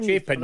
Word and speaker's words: Chapin: Chapin, yeah.